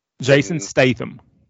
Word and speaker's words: Jason Statham: Jason Statham.